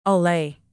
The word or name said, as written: allay